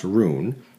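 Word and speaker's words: rūn: Current research suggests that the word run, pronounced rune, had been thoroughly assimilated into Anglo-Saxon Christianity and meant simply sharing of knowledge or thoughts.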